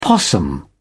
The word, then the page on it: possum: British English